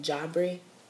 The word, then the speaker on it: Jabri: And it's actually pronounced like Jabri. So Jabri means angel of God in Swahili, Jah, and then Bri.